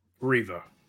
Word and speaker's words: Reva: Reva.